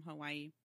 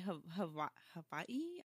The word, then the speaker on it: Hawaii: So from what I understand, the rule of thumb was if the W is within the word, like say like it's the third letter, fourth letter, whatever, then it's pronounced like a V. If it's at the beginning of a word, like say Waikiki, then it's pronounced as a W. The word Hawaii, you see this little apostrophe looking thing just between the two I's, and that's the pause.